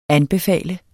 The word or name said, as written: ANBEFALES